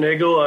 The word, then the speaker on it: Nagel: My name is Clint Nagel.